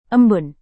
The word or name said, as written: embun